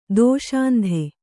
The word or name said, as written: ದುಃಸಂಧಿ